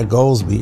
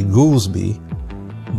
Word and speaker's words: Goldsby: And his name is not Goldsby, it's actually Goolsby, but people call it Goldsby because it's spelled G-O-L-D-S-B-Y.